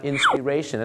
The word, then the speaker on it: inspiration: invitation เห็นไหมครับเพราะหลายคนพูดว่า invitation ก็รู้สึกแปลกแต่ปรากฏว่าพอคำว่า inspire นะครับแล้วก็ออกเสียงว่าผิดเป็น inspire นะครับ inspire เห็นสระไอเนี่ยนะฮะกลายเป็นนามปุ๊บชาวไทยก็ออกเสียงว่า inspiration แล้วรู้สึกก็โอ้นะโอเคเลยจริงๆแล้วนะครับมันเป็นอะไร inspiration นั่นเองครับ